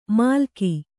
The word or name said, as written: ಮಾಲಕಿ